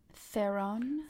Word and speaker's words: Theron: How do you say her last name? – Theron. – I always call her Theron. Theron. – No, that's so wrong.